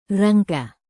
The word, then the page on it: rangka: indonesian